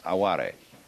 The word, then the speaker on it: aware: It's spelt like our word aware, only pronounced aware.